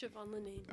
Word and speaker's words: Siobhan Linnane: Yes, Siobhan Linnane.